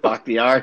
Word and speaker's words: Bakhtiari: Bakhtiari.